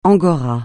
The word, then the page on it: angora: francais